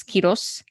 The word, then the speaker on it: Quiroz: One time I was at the airport and my last name is Quiros, or if you pronounce it properly in Spanish, it's Quiros. And so it's spelled Q-U-I-R-O-Z.